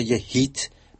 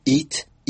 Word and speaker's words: hit: در نتیجه چون فرانسویان حرف اچ را در واژه‌ها تلفظ نمی‌کنند، واژه هیت HIT از زبان برنار کوشنر ایت EAT به معنای خوردن ادا می‌شود.